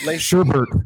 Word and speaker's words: Sherbert: Sherbert. Like the ice cream.